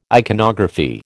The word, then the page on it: iconography: American English